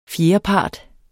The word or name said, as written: fjerdepart